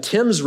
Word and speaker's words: Thames: I said that wrong in the first service. Thank you, Rob, for helping me out. The Thames River.